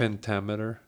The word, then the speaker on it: iambic pentameter: That was corrected for me earlier today by, Chris Adams. Shout out. Say it again. It's a Shakespearean. – Iambic pentameter. – Okay. – I'm saying that right.